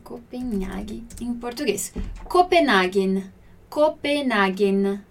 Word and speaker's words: Copenhagen: Olha a pronúncia aqui desse nosso N no final, né? Copenhagen. Ok? No italiano, quando a gente vai falar, pronunciar o M e o N, a gente tem que pronunciar como se tivesse uma letra depois ali, só que é um som mudo, né? Então é Copenhagen. Como se eu falasse Copenhagen. Só que eu não falo A, Copenhagen, ok?